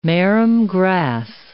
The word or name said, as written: marram grass